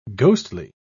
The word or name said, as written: ghostly